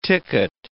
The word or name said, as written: ticket